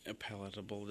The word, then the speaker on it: palatable: Palatable, did I say that right? – I think you did, yes.